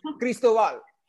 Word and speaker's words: Cristobal: Did I say your last name right? I said it like a white person. – Yeah, you did. But Cristobal. – Thank you. There it is. That Latin flair.